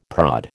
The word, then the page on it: prod: American English